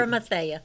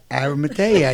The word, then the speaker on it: Arimathea: Say, what's the right pronunciation? – Arimathea. – Arimathea. I thought I said that.